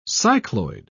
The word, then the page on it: cycloid: British English